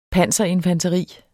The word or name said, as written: panserinfanteri